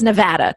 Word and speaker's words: Nevada: I had to, I wrote in my notes to make sure that it's pronounced Nevada, correct? – You got it just right.